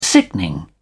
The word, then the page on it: sickening: British English